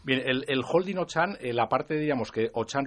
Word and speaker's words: Auchan: Que se escribe Auchan y que se pronuncia ¿cómo? – Sí, se pronuncia, la primera palabra es Auchan en francés, la segunda es en inglés Retail y la tercera en español España.